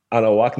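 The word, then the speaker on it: Anáhuac: She was born and raised in Mexico City in the Reforma area, went to Universidad Anahuac Norte, am I saying this right? – Yes. Perfect. Yes.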